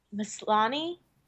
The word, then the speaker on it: Maslany: Maslany? – Yeah, did it.